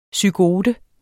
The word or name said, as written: zygote